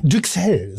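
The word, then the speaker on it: Duxelles: Wie Düxel, so heißt es. Wie Düxel. – Also ich denke, es spricht sich wie Brüssel aus. Brüssel.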